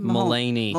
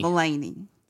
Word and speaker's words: Mulaney: It's John Maloney? How do you say his name? – Mulaney. – Mulaney.